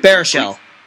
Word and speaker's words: Baruchel: Yeah, or the scene where after Jay, how's he pronounce his last name? – Baruchel.